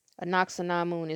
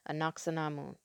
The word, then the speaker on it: Ankhesenamun: Anaxinamun is spelled A-N-K-H-E-S-E-N-A-M-U-N, Anaxinamun. Akhenaten is spelled A-K-H-E-N-A-T-E-N.